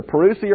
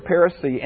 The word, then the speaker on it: parousia: It's either parousia or parousia, and it means a presence.